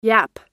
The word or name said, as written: yapp